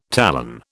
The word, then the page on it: talon: British English